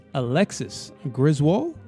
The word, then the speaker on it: Alexis Griswold: My guest today is Alexis Griswold.